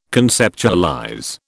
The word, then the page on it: conceptualize: British English